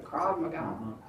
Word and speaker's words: Krav Maga: Am I saying that right? Krav Maga.